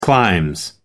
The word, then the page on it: clime: American English